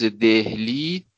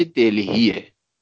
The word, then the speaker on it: دهلی: تازه اونجا بود که متوجه شدیم تلفظ دهلی دهلی نیست بلکه دلهیه.